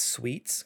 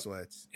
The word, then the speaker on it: Sweats: I'm ready for a nap now, but this first question comes from now I want to pronounce this sweets because it sounds nicer, but I think it's based off how it's spelled, I think it's sweats. – It's definitely so, sweats.